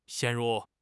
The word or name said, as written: 陷入